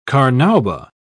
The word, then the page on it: carnauba: British English